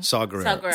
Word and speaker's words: Saw Gerrera: Saw Gerrera.